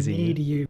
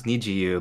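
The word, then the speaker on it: NiziU: Oh, it's yeah, it's spelled Nizu, but it's actually pronounced Need-You. Pretty much. – Need you? Okay. Yeah, I thought it was pronounced Niji-you because of the rainbow. – Yeah, but they pronounce it similar to Niji-you for the English speakers. But oh. Yeah, it's supposed to be more like the rainbow pronunciation.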